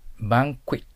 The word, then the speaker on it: banquet: で B ね、 Banquet。 Q の発音があるときには、クイックイッと発音しないといけませんよ。バンクイットとなります。今夜は盛大なバンクイットをしよう。